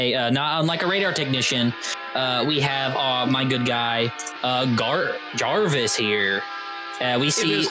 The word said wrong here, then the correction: Garvis: a sort of Garvis with a hard G.